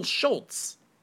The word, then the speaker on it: Schulz: It's Schultz. – There's no T. – It doesn't matter. It's still matter. – It does matter. You can't pronounce something with a T when there's no T. – It's how it's pronounced. It's just how it was spelled, but it's still Schultz.